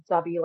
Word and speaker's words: Dobby: So my name is Dobby, like spelled like Harry Potter, D-O-B-B-Y.